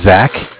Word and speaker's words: Zach: My name is Zach, spelled Z-A-C-H. I'm giving a follow-up call here from Bank of Cardiff. Cardiff is spelled C-A-R, like car, D-I-F-F, as in different.